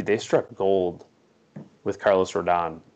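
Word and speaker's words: Rodón: Rodon.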